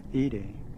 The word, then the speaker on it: Ede: The A in the first syllable of Ede almost rhymes with the English word play.